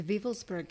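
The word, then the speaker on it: Wewelsburg: The Wevelsburg Castle. – Oh, I said it wrong.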